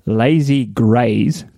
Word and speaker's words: Lazy Grays: Oh, no, I stopped at Ekatahuna at the Lazy Grace. No, let me say this right. Lazy Grays, Grays with a Z.